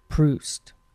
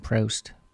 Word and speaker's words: Proust: And I've been mispronouncing it all these weeks. It's Paging Mr. Proust. I've been saying Proust, P-R-O-U-S-T. I guess that learns you what I know about literature. I actually like literature. I just haven't read any Proust. I didn't know how to pronounce the name.